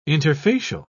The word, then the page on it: interfacial: British English